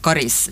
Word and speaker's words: Karis: Men det här dialektala uttalet med kort A, Karis, det är äldre.